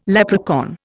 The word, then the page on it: leprechaun: Indian